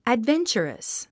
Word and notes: adventurous: ædvéntʃərəs; アドベンチャラス

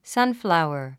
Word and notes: sunflower: sʌ́nflàuə r; サンフラワァ

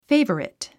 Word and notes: favorite: féivərit; フェイバリット

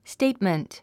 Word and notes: statement: stéitmənt; ステイトメント

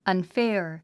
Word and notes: unfair: ʌnfέə r; アンフェア

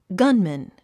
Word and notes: gunman: gʌ́nmən; ガンマン

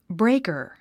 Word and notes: breaker: bréikə r; ブレイカァ

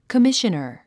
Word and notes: commissioner: kəmíʃənər; コミショナァ

